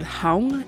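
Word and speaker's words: không: The next word is the word hong, and it is spelled K-H-O-N-G, and again, the O has the circumflex accent above it,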